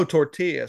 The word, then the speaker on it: tortilla: Or like, oh, tortilla. So the other kind are still on the board. Okay, cool. – Yeah. – Hey, I appreciate you correctly pronouncing that double L right there.